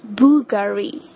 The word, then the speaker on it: Bulgari: The next one，我个人很喜欢的一个珠宝的一个品牌哈，宝格丽，正确的发音应该是 Bulgari，重音在第一个音节 Bulgari，我之前也曾经念错过 Bulgari，应该是 Bulgari， OK， Bulgari。